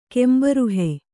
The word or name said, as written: ಕೆಂಬರುಹೆ